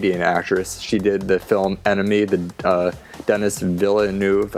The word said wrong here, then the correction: Denis Villeneuve: I can never pronounce his name. – Denis Villeneuve. Okay, well, I'm going to have to learn to do that by the time Arrival opens this fall.